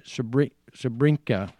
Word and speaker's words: Srebrenica: Srebrenica, which I'm not pronouncing correctly. – You got it. You're pretty good.